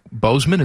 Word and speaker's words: Boseman: I know he wasn't Jackie Robinson, but he was in the movie that Chadwick Boseman, is that how you say his name? – Yeah, he what a heartbreaking story.